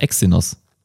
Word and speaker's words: Exynos: Äh, also ich kenne dies als Aussprache Exynos. Exynos.